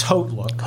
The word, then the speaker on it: HauteLook: So can we get an official pronunciation? – It's Haute Look. – Haute Look. So I am correct that I'm not saying it right. – Think French.